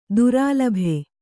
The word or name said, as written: ದುರಾಲಭೆ